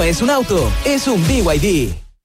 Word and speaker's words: BYD: ¿Los BID? – ¿BID? ¿No es BID? – Nada que ver. Se escribe BID, pero se dice BYD.